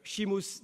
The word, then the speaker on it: Seamus: Quelques noms, peut-être que vous rencontrerez, Declan Kibbird, David Lloyd, et surtout, alors là c'est du gaélique, alors c'est difficile à prononcer, Seamus Dean, mais ça s'écrit S-E-A-M-U-S, qui incarne ce courant qui propose d'appliquer ou d'adapter en Irlande les problématiques et la méthodologie associée au postcolonialisme.